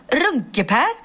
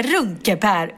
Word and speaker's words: Runkeeper: Och då insåg jag att Runkeper uttalas inte Runkeper utan Runkeper. Runkeeper, Runkeper.